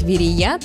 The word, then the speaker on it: Верея: Что наши предки имели в виду, когда называли город Верея, точно неизвестно. Однако ударение в этом слове только на последний слог. Верея.